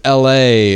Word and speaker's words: ELHAE: He is the artist from North Dakota who lives in Atlanta, but his name is LA. But it's not spelled LA like the city. It's spelled ELHAE.